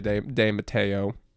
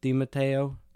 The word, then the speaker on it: De Matteo: Actually, IMDb says that it's pronounced Dre De Matteo.